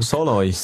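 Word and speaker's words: Szalai: wie spricht man aus von Freiburg? – Solai. – Ich glaube Solai, ja.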